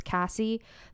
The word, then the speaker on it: Cassy: Even when people don't even know how my name is spelled, even if I just say my name is Cassie, they'll be like, okay, Casey, follow me here.